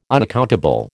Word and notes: unaccountable: American English

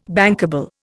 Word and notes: bankable: Indian

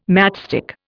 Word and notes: matchstick: Indian

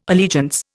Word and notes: allegiance: Indian

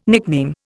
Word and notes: nickname: Indian

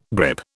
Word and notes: grip: British English

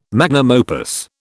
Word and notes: magnum opus: British English

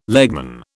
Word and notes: legman: British English

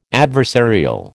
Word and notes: adversarial: American English

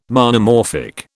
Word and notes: Monomorphic: British English

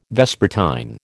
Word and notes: vespertine: American English

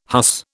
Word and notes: huss: British English

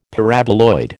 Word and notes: Paraboloid: American English

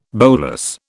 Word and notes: bolas: British English

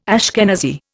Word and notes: Ashkenazi: Indian

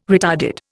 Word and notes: retarded: Indian

